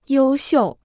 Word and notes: 优秀: yōu xiù

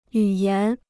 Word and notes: 语言: yǔ yán

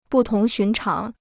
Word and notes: 不同寻常: bù tóng xún cháng

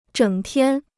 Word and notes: 整天: zhěng tiān

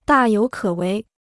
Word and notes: 大有可为: dà yǒu kě wéi

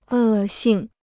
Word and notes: 恶性: è xìng

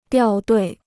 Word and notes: 掉队: diào duì

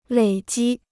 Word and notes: 累积: lěi jī

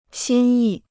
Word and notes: 心意: xīn yì

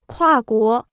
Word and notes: 跨国: kuà guó

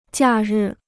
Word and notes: 假日: jià rì

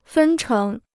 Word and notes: 分成: fēn chéng

